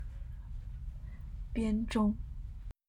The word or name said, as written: Bianzhong